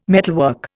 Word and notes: metalwork: Indian

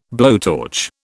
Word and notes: blowtorch: British English

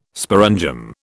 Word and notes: sporangium: British English

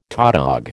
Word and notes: tautog: American English

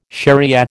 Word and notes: shariah: American English

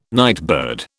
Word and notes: nightbird: British English

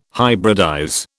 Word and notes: hybridize: British English

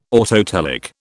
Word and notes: autotelic: British English

